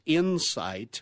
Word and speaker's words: Incyte: So that stock, it's Insight, again, symbol I-N-C-Y, and it's spelled Insight, I-N-C-Y-T-E, and that one's a biotech.